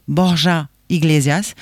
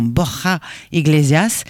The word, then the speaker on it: Borja Iglesias: Je vais prononcer son nom à la française, Borja Iglesias. En espagnol, ça donnerait un Borja Iglesias.